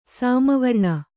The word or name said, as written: ಸಾಮವರ್ಣ